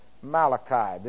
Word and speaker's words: Malachi: And then you have, this is silent. This radical right here is silent. So we'll pick up the vowel, and that's an A. So we have Mal. And then we have the C-H-I right here, Malachi.